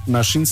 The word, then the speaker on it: Naxin: Nashin se escribe Naxin. Y es en mazateco, que significa venadito.